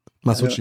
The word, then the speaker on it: Masucci: Hat der Oliver Mansucci, wird er so richtig ausgesprochen? – Mansucci. – Masucci. Ja, ja.